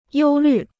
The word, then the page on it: 忧虑: yōu lǜ